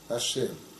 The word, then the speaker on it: Ашем: Мы произносим иносказательно Ашем, потому что Шем это имя, собственно, имя на иврите Шем.